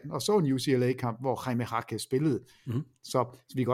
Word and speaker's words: Jaime Jaquez: Du siger det rigtig godt. – Nå, jeg er blevet bedre. – Du glemmer bare lige at sige junior. – Junior. – Jaime Jaquez junior.